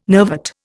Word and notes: nervate: Indian

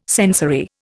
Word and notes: sensory: Indian

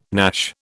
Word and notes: gnash: British English

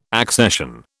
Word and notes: accession: British English

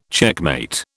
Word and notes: checkmate: British English

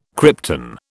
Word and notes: krypton: British English

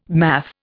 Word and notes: math: Indian

